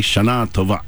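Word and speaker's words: Shana Tova (שנה טובה): But if you're in Israel, it'd be Shana Tova. So you hit the last syllable if you want to really make things sound like you're in Israel.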